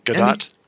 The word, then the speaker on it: Gadot: Gadot. – Gal Gadot.